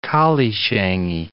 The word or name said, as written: collieshangie